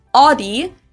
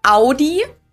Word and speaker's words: Audi: Most Americans that I know pronounce it like I just said it, Audi. In German, we say Audi, Audi.